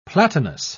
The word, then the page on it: platinous: British English